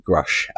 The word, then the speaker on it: Grusch: Grusch.